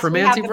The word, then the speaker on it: Primanti: And then what about, which is kind of proliferating now, Primanti Brothers? Am I saying that right? – Yes, Primanti Brothers? – Yeah.